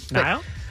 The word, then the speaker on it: Niall: I feel like I've been saying his name wrong all morning. – Niall? – That's right.